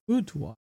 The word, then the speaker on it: boudoir: Boudoir.